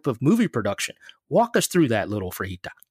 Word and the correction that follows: frijolita: First of all, I need to work on your pronunciation because it's little frijolita.